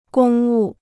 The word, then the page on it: 公务: gōng wù